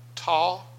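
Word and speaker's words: Tah: And by the way, I pronounced it Thai, and you said it's actually Taw? – Ta. – Ta. – Yeah. – Ta. All right? So I'm correcting myself and correcting. It's Ta. It's T-A-H.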